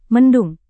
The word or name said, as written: mendung